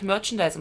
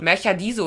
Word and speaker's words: Merchandise: Oh, bis dahin habe ich Merchandise immer falsch ausgesprochen. – Jetzt kannst du. Hm? Was hast du denn noch so für... – Märcher Diese oder sowas habe ich immer gesagt.